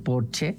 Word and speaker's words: Porsche: O decir que tengo un Porsche en lugar de decir tengo un Porsche, porque en alemán Porsche se dice Porsche porque todas las S al final se pronuncian, como Marlene Dietrich, por ejemplo, que todo el mundo dice Marlene, pero es Marlene.